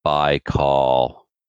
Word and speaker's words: Baikal: It's pronounced Baikal. – Aw, Captain Andy? – As in Lake Baikal.